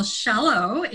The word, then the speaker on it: Shello: Well, Shello is my name. It's pronounced Shell with an O at the end.